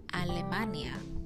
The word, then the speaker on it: Alemania: The next one is Alemania. Alemania.